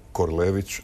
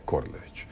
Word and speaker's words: Korlević: Ali hrvatski standard ima jedno pravilo koje kaže da imena treba izgovarati onako kako ih izgovaraju njihovi korisnici. I zato je Korado Korlević, a ne Korlević.